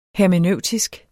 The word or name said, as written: hermeneutisk